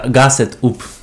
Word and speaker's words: Gassed Up: Grasset Up. – Czy tam jest R? – A nie ma. Gassed Up. Gassed Up. Tak się montaż nazywa.